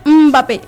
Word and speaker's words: Mbappé: Y bueno, el primer gol llegó al minuto 60 cuando Kylian Mbappé, porque él nos dijo que así se pronuncia su nombre, Mbappé, es que luego le decimos Mbappé, pero sin la E, sería Mbappé, apareció con acento en la E, apareció con un disparo colocado para romper el cero apenas 6 minutos después.